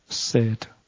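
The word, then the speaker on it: 헤세드 (חסד): 헤세드. 이 단어는 외웁시다. 나중에 히브리어가 어떻게 생겼는지 보여줄 텐데 저 단어는 안다. 헤세드, 헤세드. 헤라고 발음이 됩니다. KH 발음이 나옵니다.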